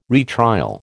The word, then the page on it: retrial: American English